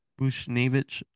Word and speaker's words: Buchnevich: For Pavel Buchnevich, is that how you say his name is? – Yeah. – Buchnevich.